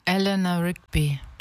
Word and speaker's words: Eleanor Rigby: Eleanor Rigby? – Eleanor Rigby.